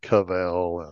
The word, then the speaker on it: kvell: It's K-V-E-L-L. I don't even know. Kvell? – Kvell? – K-V-E-L-L. – Kvell? Like Vell, only with a K on the front. Kvell.